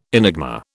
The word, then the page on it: enigma: British English